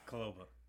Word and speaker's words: Kuloba: Kuloba. – Kuloba? – Yeah. – Yeah, that's a good pronounce. – All right. It's all right, as long as you say the K.